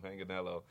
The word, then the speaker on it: Manganiello: Did I say that right? Manganiello. Yep. Joe Manganiello, Matt Bomer. – Oh, it's Manganiello. – Manganiello. – I said it. He was sitting right here where you are, and uh-huh. I said it wrong, and he told me in front.